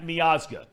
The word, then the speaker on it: Miazga: Matt Miazga, correct? – That's right.